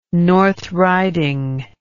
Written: north riding

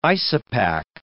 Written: isopach

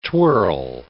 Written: twirl

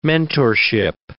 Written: mentorship

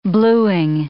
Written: bluing

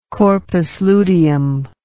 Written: corpus luteum

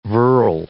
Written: virl

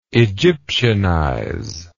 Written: Egyptianization

